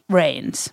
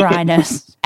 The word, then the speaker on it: Reines: Our guest today is Philippe Reynes. – It's Reynes. Can't say someone's a good friend and then mispronounce their last name. – Sorry, our guest today is Philippe Reynes.